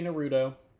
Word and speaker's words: Naruto: A lot of people say Naruto, but it's pronounced Naruto.